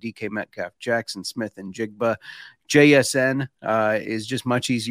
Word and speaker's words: Njigba: So I saw this pronunciation guide thing that was allegedly from the actual NFL draft, and they said it was Njigba.